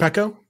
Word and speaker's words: Morpeko: I don't know how it's pronounced. – Morpeko. Yeah.